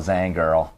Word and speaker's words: Zangerl: How is that for pronunciation of the last name? – A little bit different. Okay. Normal, it's Zangerl, but it's impossible Zangerl. To say it. – Zangerl. Okay.